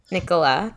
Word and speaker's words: Nikola: Nikola? – Nikola Tesla.